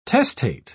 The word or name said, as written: testate